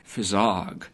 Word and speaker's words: phizzog: Fizzog, which is his own word, in which he spells P-H-I-Z-Z-O-G.